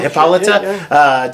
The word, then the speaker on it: Hippolyta: Hippolyta? Yeah, Hippolyta.